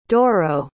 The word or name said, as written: Doro